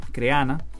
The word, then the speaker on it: Crehana: En este caso te quiero comentar un curso que estoy haciendo en la plataforma Creana. Se escribe C-R-E-H-A-N-A, Creana.